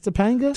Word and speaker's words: Topanga: Did you say Topanga? – It says, maybe I mispronounced it. – From it's Boy Meets World? – T-O-P-A-N-G-A. – That's it? – That's Topanga? – Topanga.